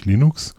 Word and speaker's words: Linux: Den Soundtrenner, den ihr in dieser Episode hört, das ist Linus Torvalds, der vor Jahren einen Namensstreit mal dadurch beigelegt hat, dass er ausgesprochen hat, wie man Linux spricht, nämlich Linux. Ich habe einige Kollegen, die sprechen Linux, Linux und das ist leider falsch.